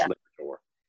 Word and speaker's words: Liberatore: I always want to add an E or an at the end of it, Liberatore or E, or, but it's just Liberatore.